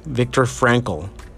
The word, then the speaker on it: Viktor Frankl: When Viktor Frankl, I'm gonna butcher his name, Viktor Frankl, there you go.